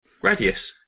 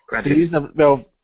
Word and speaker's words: Gradius: Is it Gradius or Gradius? – Gradius. Yeah, Gradius. Absolutely. Gradius.